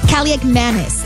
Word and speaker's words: Kaliakmanis: Washington losing quarterback Marcus Mariota to a knee injury, being replaced, yes, by Ethan Kaliakmanis. That is correct. That is how you say it.